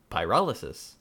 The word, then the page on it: pyrolysis: American English